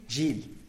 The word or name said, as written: zhily